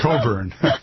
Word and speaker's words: Cockburn: And in fact, some of the visuals that I'm looking, Bruce Coburn is spelled C-O-C-K. B-U-R-N, like something, you know, you would get from having sex on the rug, painful. – On a rug or something like that. But it's pronounced Coburn.